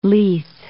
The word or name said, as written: Leith